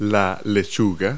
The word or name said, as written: lettuce